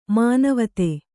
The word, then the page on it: ಮಾನವತೆ: Kannada